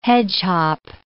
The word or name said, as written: hedgehop